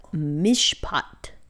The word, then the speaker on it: mishpat: The Hebrew word for decision here is mishpat.